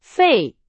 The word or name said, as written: 费